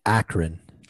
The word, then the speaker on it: Akron: It's Akron.